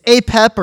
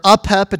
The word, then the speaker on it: Apep: The enemy of Ra is named, it's Apep or Apep, it depends on how you want to pronounce it. It's A-P-E-P.